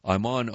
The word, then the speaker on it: Ayman: And before we begin, Iman O'Day. Iman, first name is spelled A-Y-M-A-N. Last name, O'Day, is spelled O-D-E-H.